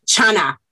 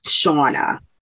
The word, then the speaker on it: Shauna: So when they say my name, like my cousins, like in stuff in Puerto Rico, it's Chana. I'm like, oh, Chana. – Mm-hmm. Chana. – Right. – Chana. – And actually, my mom is over here saying you're still not pronouncing your name right. It's really Shauna.